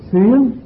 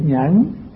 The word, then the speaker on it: nhẫn: Có chỗ đọc là xuyến mà đúng đó là chữ nhẫn, nhưng mà đọc xuyến thì cái âm nó hợp với đoạn dưới.